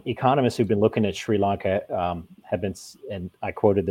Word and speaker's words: Sri Lanka: Some people say Sri Lanka, but I think it's pronounced Sri Lanka.